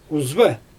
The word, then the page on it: uzva: Xyzyl